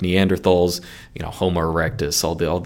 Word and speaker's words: Neanderthal: Now, first off, I will say, I call them Neanderthals. If you talk to any scientists, typically they call them Neanderthals. That is, pronouncing the TH as a T because it's based on the German pronunciation.